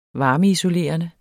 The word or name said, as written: varmeisolerende